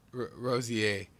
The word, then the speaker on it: Rozier: Rozier. – Rozier. – The R is like a Y.